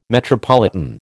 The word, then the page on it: metropolitan: American English